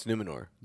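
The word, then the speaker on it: Númenor: But we also knew that, like, the human army from Numenor, did I say it right? I swear, every freaking word I say in this review. – Numenor? – Yeah, it's Numenor.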